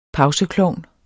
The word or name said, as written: pauseklovn